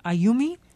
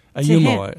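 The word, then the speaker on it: Ayumu: Well, in fact, talking about a memory task, you write about a chimpanzee, Ayumi. Am I pronouncing Ayumo? Ayumo, I believe, yeah.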